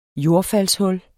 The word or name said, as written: jordfaldshul